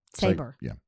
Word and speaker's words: Sabre: How do you pronounce S-A-B-R-E? – Saber. – Fiddle Saber. At Fiddle Saber.